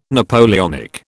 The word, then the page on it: Napoleonic: British English